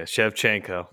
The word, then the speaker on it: Shevchenko: Yeah, Shevchenko.